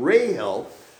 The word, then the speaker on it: Rahel: Thus saith the Lord, a voice was heard in Ramah, lamentation and bitter weeping, Rahel. Now you notice there, it doesn't say Rachel, Rahel.